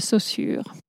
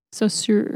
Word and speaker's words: Saussure: I'm just going to pronounce it the French way because it's easier for me. Ferdinand de Saussure. Saussure, if you prefer.